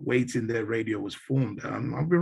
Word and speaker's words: WetinDey: It's a West African slang, which means what's happening, you know, waiting day, you know. So if I was to spell it, it's by saying waiting and day, well, it's pronounced Waiting Day.